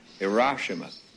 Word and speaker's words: Hiroshima: It's pronounced Hiroshima. If you're going to remove it from the face of the earth, you ought to know how to say it.